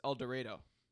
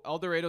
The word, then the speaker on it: El Dorado: This is actually pronounced El Dorado. – Oh, okay. So El Dorado is a town in Kansas, and it is pronounced that way as opposed to El Dorado.